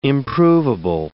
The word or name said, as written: improvable